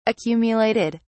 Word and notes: accumulated: British English

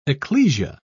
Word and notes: ecclesia: British English

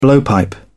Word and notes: blowpipe: British English

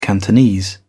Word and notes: cantonese: British English

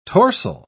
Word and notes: torsel: British English

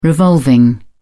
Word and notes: revolving: British English

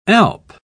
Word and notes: alp: British English

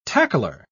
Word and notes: tackler: British English

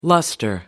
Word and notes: lustre: American English

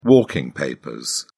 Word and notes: walking papers: British English

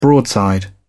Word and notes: broadside: British English

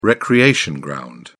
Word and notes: recreation ground: British English

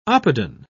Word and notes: oppidan: British English

